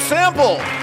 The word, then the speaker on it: Sample: Please welcome Joe Sample. – Did we pronounce that right? – Sounds good to me. – Yeah? – It's not Sample. – No, it's like free sample.